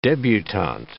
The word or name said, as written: debutante